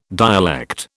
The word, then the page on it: dialect: British English